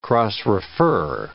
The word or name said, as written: cross-refer